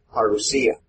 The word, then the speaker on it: parousia: Parousia. The accent is on the end. I've heard people pronounce it the way you did, but the accent's on the end. Go ahead. Parousia.